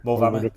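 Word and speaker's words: Moel Famau: Shall I try and pronounce Molfamu? – Molfamu, yeah, yeah, up Yeah. – Close.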